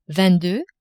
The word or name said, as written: vingt deux